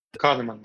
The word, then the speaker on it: Kahneman: And how do you pronounce his last name? Kahneman? – Kahneman, that's right.